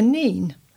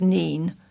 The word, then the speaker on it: Nene: Recently, a local TV newsreader was talking about the River Nene. I have always pronounced it as Nene, but she said the River Nene.